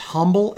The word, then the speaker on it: Humble: So, number one, a special education kindergarten teacher in Humble spelled H-U-M-B-L-E. That's pronounced Humble everywhere else, but it's Humble, Texas,